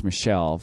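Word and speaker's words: Michel: No, which is pronounced Michelle for whatever dumb reason.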